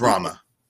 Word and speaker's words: Rama: It's Rama. – Rama, yeah.